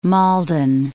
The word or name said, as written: Malden